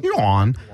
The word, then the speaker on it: yuan: Is that how you pronounce their coin? – Yeah, yuan.